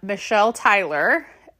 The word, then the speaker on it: Michelle Tyler: My closet name is Michelle988, which is spelled M-Y-S-H-E-L 988, and I am Michelle Tyler, M-I-C-H-E-L-L-E-T-Y-L-E-R-R, so one extra R on Instagram.